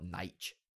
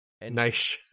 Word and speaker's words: Nietzsche: I'm just going to call it Nietzsche. – Nietzsche.